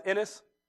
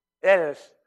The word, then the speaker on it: Innes: I think that's how you say it in Scottish. I-N-N-E-S. Innes? Say it for me. Innes. All right. This is Innes.